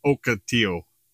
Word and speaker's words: Ocotillo: It's like Ocotillo. I thought it was Ocotillo this whole time. – Ocotillo? – It's Ocotillo. The proper pronunciation is Ocotillo.